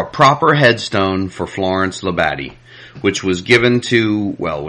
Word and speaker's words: Labadie: Yes, Labadie, Labadie. It's French, and no accent on any syllable.